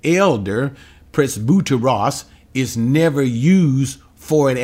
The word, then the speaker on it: presbyteros: Now, the word here used for elders is presbyteros, that's the right pronunciation.